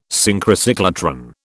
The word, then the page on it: Synchrocyclotron: British English